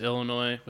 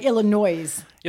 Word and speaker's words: Illinois: Well, they do say like Illinois. – Yeah, but it's spelled with an S on the end. – No, but it's a silent S.